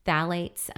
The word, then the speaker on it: phthalate: Phthalates or phthalate esters are esters of phthalic acid. And that's spelled with a PH, but it's kind of said phthalate.